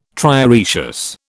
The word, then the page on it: trioecious: British English